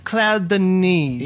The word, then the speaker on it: Claire Denis: How do you say Denis or Denise? – It's French. – Oh, okay. – I don't know how to pronounce anything. – Claire Denis.